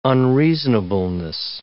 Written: unreasonableness

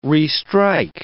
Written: restrike

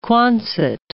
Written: Quonset

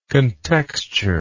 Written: contextured

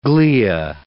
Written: glia

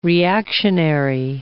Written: reactionary

